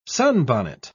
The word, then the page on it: sunbonnet: British English